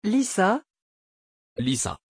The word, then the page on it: Lyssa: French